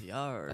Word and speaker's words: Gallardo: Gallardo.